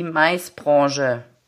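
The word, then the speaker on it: MICE-Branche: hast gesagt, es sollte, ihr habt euch so ein bisschen auf das Wording Channel Manager für die Maisbranche. Habe ich es richtig gesagt? Ja, genau.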